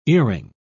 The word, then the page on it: earing: British English